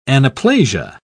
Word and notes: anaplasia: British English

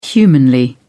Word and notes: humanly: British English

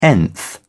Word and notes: nth: British English

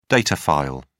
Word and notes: data file: British English

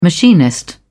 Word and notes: machinist: British English

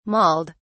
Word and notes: mauled: British English

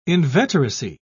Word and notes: inveteracy: British English

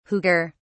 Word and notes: huger: British English

